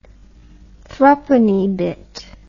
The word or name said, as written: thrupenny bit